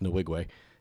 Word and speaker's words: Nwigwe: And Nwigwe is pronounced Nwigwe. He's married to a woman named Fat, F-A-T.